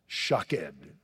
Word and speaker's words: shaqed: Because the Hebrew word almond is pronounced shaked, S-H-A-Q-E-D.